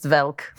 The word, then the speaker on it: Welk: My grandparents watched Lawrence Welk. – Yeah, of course. – Which they pronounced Velk. – Right, Lawrence Velk.